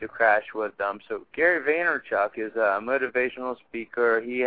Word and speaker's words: Vaynerchuk: Gary Vaynerchuk.